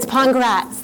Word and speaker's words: Pongrats: It's Pongrats. Like, congrats. It rhymes with congrats.